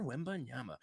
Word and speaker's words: Wembanyama: Victor Wembanyama. I am sure I'm butchering that, and I apologize. I'm trying to get it. Did I get it? Okay. – Yeah. Right. Yeah, you definitely got it.